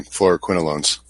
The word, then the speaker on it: Fluoroquinolones: that you don't want fluoroquinones. I think I said that wrong. Fluoroquinolones.